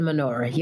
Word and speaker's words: menorah: But in the hotel in Greenbelt, they had before the transition, they had a menorah up there too. Did I say that right? – No. Yes. Yes, it is a menorah. You're right.